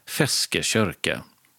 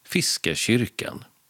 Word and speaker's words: Feskekörka: Och det var ju varken räktrålan eller silltunnan utan Feskekörka. Eller med mer normalt svenskt uttal Fiskekyrkan.